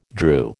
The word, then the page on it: drew: American English